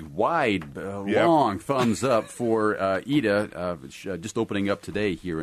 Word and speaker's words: Ida: Because that's how they pronounce it in Ida. – The film. It's spelled IDA. So if you're looking for EEDA or something like that, you're not going to find it.